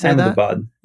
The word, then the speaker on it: Ahmedabad: And the city is Ahmedabad? – Ahmedabad. Yeah.